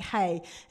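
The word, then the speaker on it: hey (ה): that it's pronounced well kind of like hey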